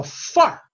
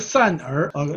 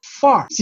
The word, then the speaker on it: 范儿: 哎，这个词非常的北京哈，这叫范儿，不是范儿。让我给大家好好的发音一下，这个词读作范儿。